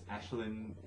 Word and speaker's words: Aisling: Her name is Ashlyn. I think is how it's pronounced, but it's spelled A-I-S-L-I-N-G, so I probably got it wrong,